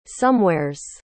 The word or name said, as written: somewheres